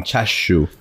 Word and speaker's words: chashu: Chashu. I'm so sorry. I said that wrong. Chashu.